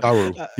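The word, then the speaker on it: Garou: On stage at EVO 2022, SNK announced that a new entry in the long-dormant Fatal Fury / Garu series, I don't know how to pronounce that one. – Garu, yeah. – Garu? Okay, perfect.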